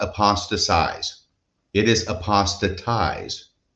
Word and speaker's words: apostatize: I think Mormons are about the only person who use the verb form of apostasy, and more than 50% of the time they mispronounce it. So it's apostatize.